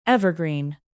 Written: evergreen

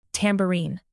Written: tambourine

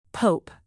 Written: pope

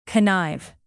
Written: connive

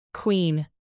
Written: queen

